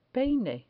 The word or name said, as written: peine